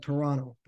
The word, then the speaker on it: Toronto: You said Toronto. You didn't pronounce the second T. You're ahead of the game. Yeah. You're ahead. That's how we say it.